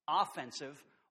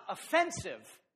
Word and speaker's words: offensive: So let's talk first about offensive or positive apologetics. And be sure to get the accent on the right syllable there. Not offensive apologetics. Offensive. Think football, okay? Offense and defense.